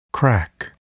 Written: crack

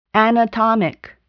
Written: anatomic

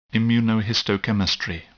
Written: immunohistochemistry

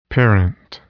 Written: parent

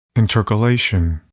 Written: intercalation

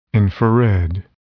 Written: infrared

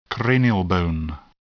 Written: cranial bone

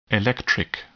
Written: electric

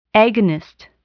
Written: agonist

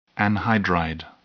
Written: anhydride

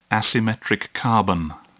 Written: asymmetric carbon

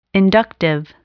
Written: inductive

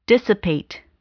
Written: dissipate

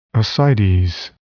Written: ascites

